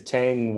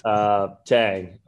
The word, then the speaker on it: Thanh: My name is Thanh Nguyen, kind of like the old astronaut drink. – Nice. – Thanh, and Nguyen, even though it's spelled N-G-U-Y-E-N.